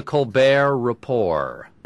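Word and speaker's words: Colbert Report: The Colbert Rapport. – Rapport? – Two silent T's. – So you should sound French in both cases.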